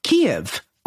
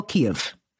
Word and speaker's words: Kyiv: Olga, how do you spell Kyiv? – K-Y-I-V, not K-E or whatever the other, the Russian way is. How do they spell it? K-I-E-V? – With an K-I-E-V. – I-E-V. Yeah.